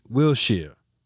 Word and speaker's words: Wiltshire: Having just listened to the new Jackie Stewart episode, I wanted to let you know that when a UK place name ends in Shire, it's pronounced like Shear, not Shire. E.g. Wiltshire.